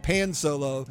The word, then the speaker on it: Pan Solo: But like, if you're reading it in English, it would look like pan solo. – Yeah. – But part of it is pan rhymes with Han. So yeah. I'm using the Spanish pronunciation.